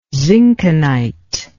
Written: zinkenite

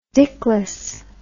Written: dickless

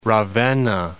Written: Ravenna

